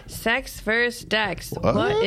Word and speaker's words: CEX: And I wanted to clarify that is CEX versus DEX. – Oh. – Yeah, it's a soft C.